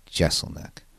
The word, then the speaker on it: Jeselnik: I don't mean to mispronounce his name. I always hear people say Jeselnik. It's actually Jeselnik.